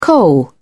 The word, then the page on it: coal: British English